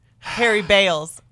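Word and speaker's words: Baals: Before yesterday, Harry Balls had a few hundred votes. Got mine. As of last night, 10,000 votes for Harry Balls. – Harry Bales. – No, that's how his family pronounces it now.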